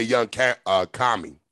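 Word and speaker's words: Young Kami: K-A-M-I. – Okay, Young Common. Gotcha. K-A-M-I.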